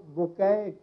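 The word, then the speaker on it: Bucaille: M A U R I C E B U C A I L L E, لیکن وہ اس کا pronunciation جو تلفظ اس کا ہے وہ بوکے پڑھتے ہیں اس کو, یہ فرانسیسی لفظ ہے, مجھے بھی پتہ نہیں تھا.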